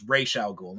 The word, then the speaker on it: Ra's al Ghul: It's Ra's al Ghul now.